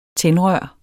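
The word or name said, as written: TÆNDRØR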